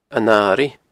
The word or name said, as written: An Airigh